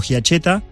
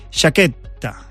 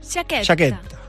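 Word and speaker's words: Giachetta: A ver, a veces ni yo lo digo bien, porque en argentino lo digo giacheta, estoy muy acostumbrado a decirlo giacheta, pero en realidad es italiano y se dice Chaqueta.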